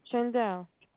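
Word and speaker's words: Shaindel: S is in Sam, H-A-I-N-D-E-L. – C-E-L? – D as in David, E-L. – Okay, D-E-L. Okay, and how do you pronounce that? – Chandel.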